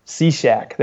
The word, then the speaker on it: Cishek: I know I'm going to pronounce his last name wrong, but Cishek. – Yes, Cishek? You got Cishek? It. Steve Cishek. – Cishek. There you go. Like a Cishek. – Cishek. Okay. Yeah. Shek. Okay. Like Shek West.